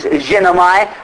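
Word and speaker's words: ginomai (γίνομαι): And this word fulfilled here is the Greek word, again, I think the pronunciation of this is ginomai, which is G-I-N-O-M-A-I. G-I-N-O-M-A-I.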